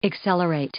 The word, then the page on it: accelerate: verb